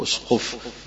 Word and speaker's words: الأسقف: والأسقف تقرأ كده أو تنطق أسقف بهمزة مضمومة وسين ساكنة وقاف وفاء مشددة.